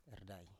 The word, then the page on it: erdai: Biak